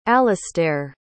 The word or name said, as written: alistaire